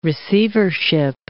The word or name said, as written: receivership